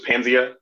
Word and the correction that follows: Paznia: Yep, it's Paznia. – Paznia, I'm sorry, man. – It's the Free Republic of Paznia. – I butchered that.